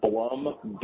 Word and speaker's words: Blum: This is Jack Blum from Park Center. – Hi, Jack. Can you say your last name again? – Blum, B-L-U-M.